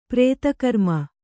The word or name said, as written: ಪ್ರೇತಕರ್ಮ